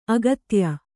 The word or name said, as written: ಆಗಾತ್ಯ